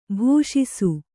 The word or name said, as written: ಭಾಷಿಸು